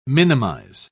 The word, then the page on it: minimise: British English